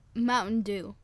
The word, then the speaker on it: Mountain Dew: Yo, para mí, conseguíme una Manadu. – No, es ¿cómo dije recién? – Manadu. – No entiendo cómo se dice. No entiendo. Manadu. – Mountain Dew. – Mountain Dew. Ahí está.